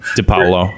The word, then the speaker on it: DiPaolo: Evidently, neither platform was willing to pay DiPaolo, whatever, how do you ever say his name? – DiPaolo.